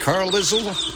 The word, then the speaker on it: Carlisle: Is that how you pronounce it? Carlisle? Carlisle, that's right.